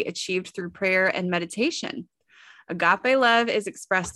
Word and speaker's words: agape: Agape.